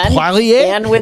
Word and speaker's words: Poirier: You have a wonderful French name. It should be pronounced Poirier.